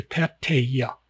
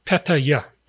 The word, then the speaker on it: Pattaya: P-A-T-T-A. Patta. Give it a little brief pause. Ya. Patta. Ya. Patta. Ya. Patta. Ya. The Y is used for the ya. It's not used for the end of the Pate. Pattaya, Pattaya.